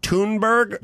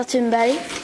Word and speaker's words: Thunberg: Greta pronounces it Thunberg. – Yeah, you want to hear it? – Yeah. – My name is Greta Thunberg.